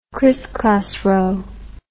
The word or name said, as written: crisscross row